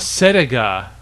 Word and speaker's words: Cedega: Um, no, it's Sediga or Sadiga or someone. – Sediga. – Sediga. Whatever. Whatever. C E D E G A.